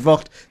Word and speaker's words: Vogt: Alice Vocht.